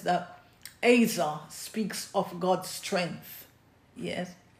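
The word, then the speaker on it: Ezer (עזר): So the word helper is the Hebrew word Ezer, A-A-E-E-Z-E-R, E-Z-E-R, but it's pronounced Ezer, A-Y-Z-E-R, Ezer.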